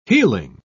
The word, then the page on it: healing: British English